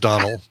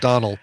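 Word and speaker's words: Domhnall: Well, I think it's pronounced Donald, it's definitely pronounced Donald, but it's spelled Domnall.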